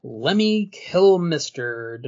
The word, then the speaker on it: Lemmy Kilmister: Lemmy Kilmister.